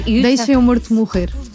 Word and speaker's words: Deixa Meu Morto Morrer: It's in Portuguese, it's very hard to pronounce Portuguese, but it's almost Portuguese. – No, Deixa Meu Morto Morrer.